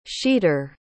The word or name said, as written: sheeter